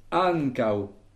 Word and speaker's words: ankaŭ: Le kau, K-A-U, chapeau, du fait qu'il y a un U chapeau, kau, c'est une seule syllabe, c'est donc la dernière. Et donc, il faut accentuer l'avant-dernière, an. Et on doit donc dire an kau.